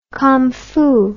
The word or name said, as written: commfu